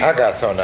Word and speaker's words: Agathon: In Greek it would be Agathon. Who knows Greek? So, when the last syllable is long, then the accent has to always in Greek to be on the second syllable. So it would be in Greek, agathon.